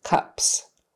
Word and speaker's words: cups: The next one is cops versus cups. So cops meaning the police, short O, ah, ah. Cups meaning little glasses, kind of things you pour water in. Cups with a short U, Here we go.